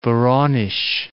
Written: Voronezh

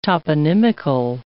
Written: toponymical

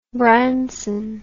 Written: Branson

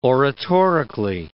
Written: oratorically

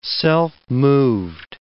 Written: self-moved

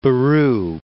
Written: Brian Boru

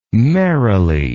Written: merilee

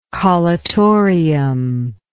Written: collutorium

